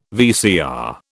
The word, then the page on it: VCR: British English